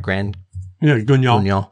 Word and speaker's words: Grand Guignol: Do I pronounce that right? – Grand Guignol.